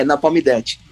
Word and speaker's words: Napalm Death: Tinha um deles que apareceu várias vezes com a camiseta do Nepal Death Agora eu falei errado, é Napalm Death.